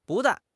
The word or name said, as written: bu4 dan4